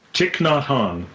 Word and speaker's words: Thich Nhat Hanh: Thich Nhat Hanh.